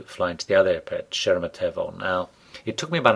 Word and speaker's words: Sheremetyevo: There's the Domo, or Domodedovo, D-O-M-O-D-E-D-O-V-O, and there's Sheremetyevo, S-H-E-R-E-M-E-T-Y-E-V-O.